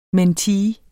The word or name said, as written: mentee